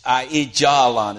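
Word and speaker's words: Aijalon: And they drive them all the way back to the coast because Ahijalon is Ahijalon is how you say it, is a city right there on the coast.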